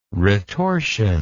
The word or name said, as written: retortion